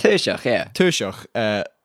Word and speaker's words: Taoiseach: However, the Irish Taoiseach, I never know if I'm saying that right. – Taoiseach, Leo Varadkar.